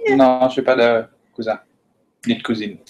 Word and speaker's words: cousin: Got to remember one thing that you really helped me with is you just don't usually or often don't pronounce the last letter in French. And that is a big, easy rule to help you. So cousin. Even though it ends in an N, cousin, say it again.